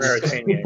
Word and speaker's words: Mauritania: Mauritania.